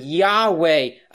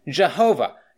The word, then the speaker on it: Yahweh: We don't actually know how it is pronounced because the Jewish scholars, when they would copy down the word of God, would only copy down the four consonants, something like YHWH in our alphabet. But we can pronounce it Yahweh. Others pronounce it Jehovah. It's the same word, but it's the proper name of God, the angel of Jehovah.